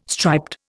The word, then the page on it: striped: Indian